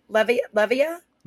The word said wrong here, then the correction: Leviev: Leviev. – Leviev? – Yes.